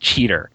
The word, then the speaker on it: Cheetor: Because we brought this up before on previous episodes, there's actually a trivia note that says that Cheater and the other characters pretty distinctly pronounce his name as Cheater. So it isn't just us like mishearing it or if they're just saying it wrong. They are actually saying Cheater instead of Cheetor for the first couple of episodes, apparently.